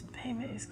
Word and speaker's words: Bebe's: Boomerang, Friday After Next, BB's, Bebe's Kids, my bad, gotta pronounce right. – Wow. – Struggling. – Did you really just say BB's? – BB's. – It's Bebe's Kids.